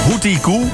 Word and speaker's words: Hoochie Koo: Als je die kent, je schrijft het gewoon als Hoochie Koo, maar je moet het uitspreken als Hoochie Koo.